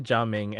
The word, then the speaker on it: Gaming: So, Ga Ming, actually, you know, the thing is, when I was listening to this live stream, when I was hearing their pronunciation, it sounded like they were calling him Ga Ming. – Oh, Ga Ming. Right, like, right. That's right. They said Ga Ming, and I was like, I wonder why they localized it with a G, but you know, it is what it is.